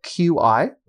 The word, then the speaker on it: Qi: I don't know if you guys, I'm sure most of you know this, but QI, when it says spelled QI, it's actually pronounced Qi.